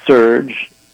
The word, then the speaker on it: SURJ: is called SURGE, and that's spelled S-U-R-J, and it stands for Stand Up for Racial Justice.